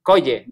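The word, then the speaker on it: Kjolle: I know you're working with her at Central, and she has, do you pronounce it? Is it Cojole? – Coye, yeah. – Coye.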